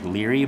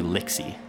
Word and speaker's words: Lixy: The font on the name by the picture made her name look like Leary, but her name is actually Lixy.